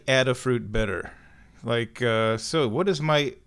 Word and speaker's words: Adafruit: I'm at one of my favorite websites, Adafruit, or it might be more accurate, more accurately pronounced, Adafruit. But I kind of like Adafruit better than Adafruit, because, so, what'd you do this morning?